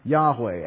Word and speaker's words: Yahweh: which we conjecture that it's pronounced Yahweh.